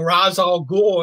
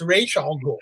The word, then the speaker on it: Ra's al Ghul: I mean, I hear people going Ra's al Ghul, and I'll go, no, it's Ra's al Ghul.